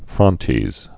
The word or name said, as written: frontes